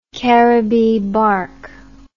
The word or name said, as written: Caribbee bark